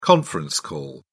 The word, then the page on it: conference call: British English